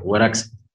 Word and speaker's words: Guarax: Claro, y decíamos también, queríamos que usted nos comente sobre la incubadora cultural Guarax Arte, no sé si lo estoy pronunciando bien. – Sí, perfecto, Guarax con tilde, la última.